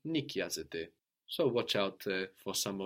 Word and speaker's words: νοικιάζεται: Αυτό το σπίτι δεν νοικιάζεται. Δεν νοικιάζεται αυτό το σπίτι. So, again, sounding the same as you plural rent, but spelled with the A-I ending, like the verb είναι, νοικιάζεται.